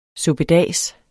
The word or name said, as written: suppedas